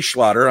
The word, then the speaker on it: Schlatter: With George Burns and Charlie Schlatter.